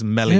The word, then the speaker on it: Meslier: One thing I want to pick up on, is it Meslier or Melier? – I believe Bryn Law pronounces it correctly as Meslier.